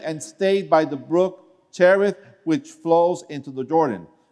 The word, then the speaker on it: Cherith: Okay, how do you pronounce that? Cherith. Okay, Cherith, which flows into the Jordan.